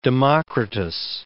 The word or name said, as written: Democritus